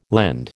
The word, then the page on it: lend: American English